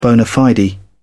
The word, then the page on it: bona fide: British English